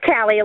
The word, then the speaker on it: Callie: It's Callie, like California.